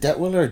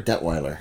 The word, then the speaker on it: Detwiller: Is it Detwiller or Detweiler? – Oh, you know, we're easy on that front. It's usually Detwiller, but... I've heard Detweiler, and it's spelled like 33 different ways, but we're all related. You find a Detwiller, or a Detweiler, or a Det, you know, 1L, that's us too.